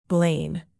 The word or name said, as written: blain